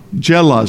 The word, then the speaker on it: Giloh: I understand that's Giloh. Jela is the way you pronounce that.